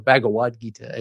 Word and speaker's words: Bhagavad Gita: And then I thought about things like the Bhagavad Gita or Bhagavad Gita, as you would pronounce it in Sanskrit.